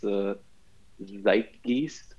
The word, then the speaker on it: Zeitgeist: Zeitgeist, that's how it's pronounced.